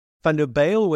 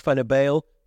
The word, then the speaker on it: Van der Bijl: He pronounced Van der Bijl the way the English do, with a crisp A sound rather than the flatter U sound.